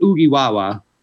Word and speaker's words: Ugiwawa: So uh, you pronounce it Ugiwawa. All right. Um, and it means cheers in Zulu.